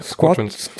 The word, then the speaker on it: Squadrons: Und zum Beispiel stört es mich sehr, dass Star Wars wie spricht man das aus? Squadron? – Ja, Squadrons.